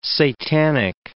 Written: satanic

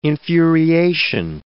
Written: infuriation

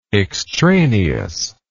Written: extraneousness

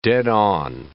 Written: dead-on

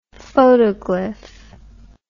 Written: photoglyph

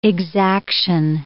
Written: exaction

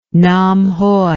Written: Namhoi

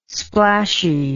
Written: splashy

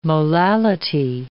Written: molality

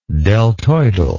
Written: deltoidal

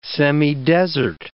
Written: semidesert

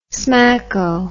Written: smacko

